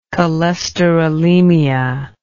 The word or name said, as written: cholesterolemia